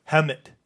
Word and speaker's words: Hemet: I think just it helmet Hemet. Minus the L. – Yeah, that's what I would say it as is Hemet.